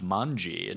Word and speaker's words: manji: It's pronounced manji in Japan.